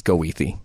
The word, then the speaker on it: Goethe: Which candidate will correctly pronounce Goethe Street? It's Goethe. We all know it's Goethe.